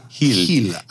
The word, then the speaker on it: Gil: And along those lines, also Carlos Gil, who I've been informed that this is how you pronounce his name. – Oh, it's a tricky one, but it sounds sexy when it rolls off your tongue. – Heel.